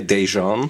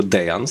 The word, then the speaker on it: Dejan: No i dobra, zrobił sobie ten system, zatrudnił fenomenalnego grafika z Serbii, tego właśnie Dejana, to jest Dejan, nie Dejan, tylko Dejan, sprawdzałem.